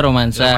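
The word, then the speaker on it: Romanssa: Iya, Romansa dibacanya Romansa. Romansa. – Dibaca Romansa, tapi kalau untuk penulisan harus double S.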